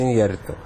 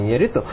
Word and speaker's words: Иньярриту: которое, безусловно, является фильмом Иньярриту, правильно говорится, Иньярриту, а не Иньярриту.